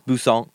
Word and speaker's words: Busan: It's kind of like French. You kind of just don't say the end, but it's Busan.